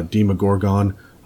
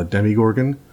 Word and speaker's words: Demogorgon: There's uh, my main man, my favorite guy ever, uh, Demogorgon. Uh, some of you will pronounce it uh, Demigorgon, um, but that is uh, that's a Stranger's Thing pronunciation.